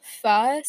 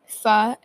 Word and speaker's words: pho: is pho. So it's spelled pho, pho, and I do pronounce it right, just so you know.